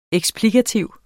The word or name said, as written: eksplikativ